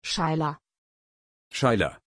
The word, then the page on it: Shaila: German